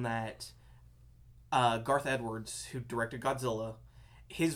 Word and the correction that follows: Gareth: Gareth, thank you.